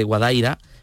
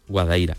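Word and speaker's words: Guadaíra: Entonces, de ahí que mucha gente, quizás en, en décadas anteriores, hablase de Guadaira, pero no, no. Eh, el, nombre correcto es Guadaira.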